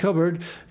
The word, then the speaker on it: Cockburn: All right, that was Miko Marks, Good Night America, and we're going to have a second here of a farewell from Bruce Coburn, spelled Cockburn, pronounced Coburn.